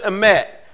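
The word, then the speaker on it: emet (אמת): The word for truth in Hebrew is written like this, but you pronounce it emet, aleph, mem, tav.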